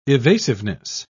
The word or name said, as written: evasiveness